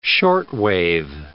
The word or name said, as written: shortwave